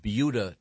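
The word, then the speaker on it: Buda: we have yet to get to sports, but we were discussing a very interesting news item from Beulah, Texas. – Mm-hmm. – Christy, you insist on saying. – No, that's how it's pronounced.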